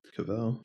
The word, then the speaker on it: Cavill: How do you Cavill? – Say his name? – Cavill.